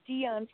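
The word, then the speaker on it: Dionne: This is Dion from Chicago.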